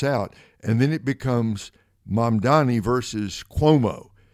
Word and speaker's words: Mamdani: How do you pronounce his last name? Mamdani? – Mamdani.